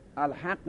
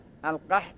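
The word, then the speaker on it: ألقح: بله الحق نها، القح منم همینطور میگم، القح نها، پس من میگم چی؟ الحق نها. ها پس من اشتباه تلفظ میکنم. القح نها، القح، القح یعنی آبستن کردن، یعنی پربار میکنند اون مثلا کشت و کار و درخت ها را.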